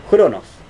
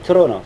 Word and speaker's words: Cronos: Por eso cuando ustedes ven anotado en los textos de historia, así, con CH, eso se pronuncia como J, Cronos. No Cronos, no, imposible decirlo así. Bien.